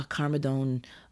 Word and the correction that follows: cardamom: or no, not carmadone. – How do you say that? Cardamom.